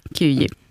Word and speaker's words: Kiev: gick över till Kiev, om det nu är ungefär så där som det uttalas. – Ungefär så, Kiev.